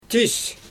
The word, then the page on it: tis: Xyzyl